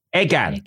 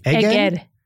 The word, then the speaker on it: Eger: So you're in, you say it, Eger? – Eger. – Eger. Am I saying it? I want to say it right. – Eger. – Yeah.